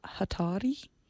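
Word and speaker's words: Jatari: We are going to be playing music by the group Hatari. I would pronounce it. It's a J, which I believe in Spanish is pronounced as an H. H is in English.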